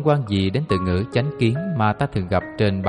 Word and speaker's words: chánh kiến: Thực hành chánh kiến. Chánh kiến tiếng Phạn là Samajithi, đôi khi cũng đọc là chính kiến, với ý nghĩa là quan niệm, cách nhận hiểu vấn đề một cách chân chánh.